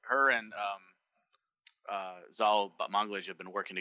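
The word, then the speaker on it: Batmanglij: We know that the main actress, Brit Marling, is also the creator of the show, along with her writing partner, Zal Balmontage. Am I saying it right? – It's Balmontage. – Balmontage.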